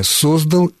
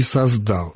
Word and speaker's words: создал: Прошедшее время «создал» и «создал», «создала», «создала» и «создала». Создали и создали, то есть в формах прошедшего времени, за исключением формы женского рода, возможны варианты ударения. Поэтому не является ошибкой «создал» и не является ошибкой «создал».